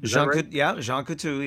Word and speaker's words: Jean Coutu: Jean Coutu? – Jean Coutu, yes. – Yeah, did I say that right? – You did.